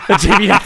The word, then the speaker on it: Gemini: Perché evidentemente gli hanno, gli hanno imposto a tenere la, la, pronuncia americana di una parola latina. Non so cosa dirti. Gemini.